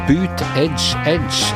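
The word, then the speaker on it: Buttigieg: Og Boot Edge Edge, slik er det du uttaler navnet på den nye og kanskje litt midlertidige favoritten i den demokratiske valgkampen i USA.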